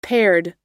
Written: paired

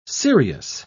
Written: sirius

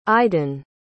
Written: iden

